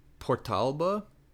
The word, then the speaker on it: Portalba: Antica Pizzeria Portalba, which is spelled P-O-R-T apostrophe A-L-B-A, which really looks like portable. – Oh, yeah, but it wouldn't be.